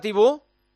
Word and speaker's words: Thibaut: Hola, Thibaut. – ¿Qué tal, Juanma? – Muy buenas. ¿Lo he dicho bien? – Muy buenas. Lo has dicho perfecto. Gracias a Thibaut Courtois, ya se pronuncia bien.